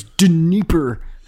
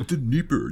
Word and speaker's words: Dnieper: You mispronounced that guy's name. – It's Dnieper. – It's Dnieper, you idiot.